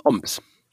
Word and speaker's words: Homs: Die haben halt kein H. Die haben das H, haben sie irgendwann mal verkauft. Und darum ist es ein Oms.